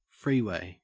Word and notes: freeway: Australian English

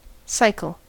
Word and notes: cycle: American English

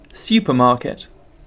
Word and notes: supermarket: British English

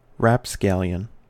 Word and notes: rapscallion: American English

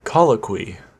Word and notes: colloquy: American English

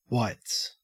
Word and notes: whites: Australian English

